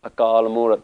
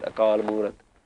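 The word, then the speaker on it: Akal Murat (ਅਕਾਲ ਮੂਰਤਿ): It doesn't mean that it's not masculine it means that you have to pronounce it with the next word. Akal Murat. That's how we know to pronounce it Akal Murat. Because Akal should have an onkar underneath it. But if it did, you'd have to stop there.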